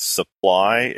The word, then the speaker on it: Svpply: But he was one of the guys who founded Supply, if but it's like spelled S-V-P-P-L-Y.